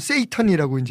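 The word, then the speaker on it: Satan: 영어로는 세이턴이라고 이제 A자가 중간에 들어가서 이렇게 발음합니다만 네.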